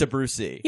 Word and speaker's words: Abrusci: It's Abrusci.